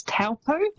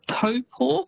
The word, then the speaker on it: Taupō: So quite often you'll hear that said as Taupō. – Yes. – It's almost how it's spelt, but that Taupō is actually pronounced Taupō.